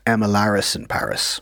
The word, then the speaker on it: Emily in Paris: I feel like I should correct your pronunciation there, because it is supposed to be pronounced as if it rhymes, the two words. So it's actually pronounced Emilaris in Paris.